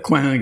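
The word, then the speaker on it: Coing: Aber er ist hugenottischer Abstammung. Also in Frankreich wäre er Coin gewesen.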